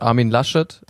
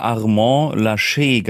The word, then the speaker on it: Armin Laschet: Herzlichen Glückwunsch, Armin Laschet. Du hattest die ganze Zeit unseren Rücken. Was ist los? – So spricht man den aus. Ich habe den immer Armand Laschet genannt.